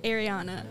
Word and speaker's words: Ariana: It's Ariana. – Okay. – It's always so confused, like Ariana, Ariane. I really don't care how you pronounce it, but I prefer my nickname, Ari.